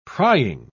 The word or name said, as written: prying